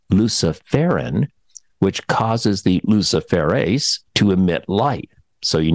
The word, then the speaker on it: luciferase: I hope I'm saying that right. I'll spell it for you. L-U-C-I-F-E-R-A-S-E. Luciferase. – Sounds right to me.